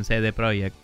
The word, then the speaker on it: CD Projekt: Que le, dicen CD Projekt en el, en el documental porque es como se pronuncia allá, supongo. – Sí, en em, polandés se dice así.